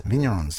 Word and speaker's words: Vignerons: And one of those is Stockman's Ridge, operated by Lisa Hambrook and her husband, Jonathan, now as board members of the Orange Region Vignerons. Do I always say that right? Yeah, cool.